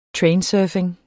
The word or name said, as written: trainsurfing